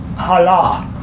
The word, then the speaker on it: halal: I see it H-A-L-A-L. But the Hebrew guy pronounced it halah.